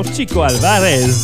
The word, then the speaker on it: Chico Alvarez: Kiko Alvarez avec la clave maraca y guiro, ou plutôt il y a même une prononciation Chico Alvarez, la clave maraca y guiro.